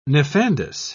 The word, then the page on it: nefandous: British English